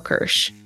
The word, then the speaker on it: Kirsh: I'm on LinkedIn at Danielle Kirsh, K-I-R-S-H.